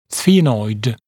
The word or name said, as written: sphenoid